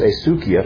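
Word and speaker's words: heisikia (ἡσυχία): But there's a word, the Greek word here is heisikia, if I'm pronouncing that right, and it means settle down. It doesn't mean complete silence. – Right. I think it's pronounced heisikia.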